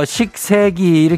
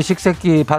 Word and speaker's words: 식세기: 그걸 왜 줄여치는 식세기를 왜 그렇게 세게 발음을 해가지고 그러는겨? 아이돌레미가. 그거 잘못해가지고 어디 아저씨 앞에 갖고 하면 큰일 나요. 어, 아저씨 우리 식새끼 봤어요?